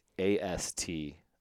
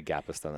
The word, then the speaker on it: Gapastione: I am my name, Tony, and the last name is spelled GAPASTIONE, and that's Italian, so you have to pronounce every syllable. Gapastione. – Yes. – Well, you can just say Gapastone, that's fine, but I'm on Insta and Twitter and all that kind of stuff and posting a lot of the creative journey.